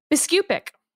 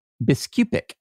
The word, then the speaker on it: Biskupic: Biskupic. – Biskupic.